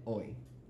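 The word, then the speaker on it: OYD: Lo próximo que viene, en dos semanas, es eh, hoy. Literalmente se escribe O y de punto. Esa canción se llama así porque todos los sonidos, las rimas acaban con el sonido hoy.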